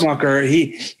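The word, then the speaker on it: Rookmaaker: So I'm going to say Rookmacher. Is that accurate or close to, is that close? – Rookmacher, in Dutch, it means smoke maker.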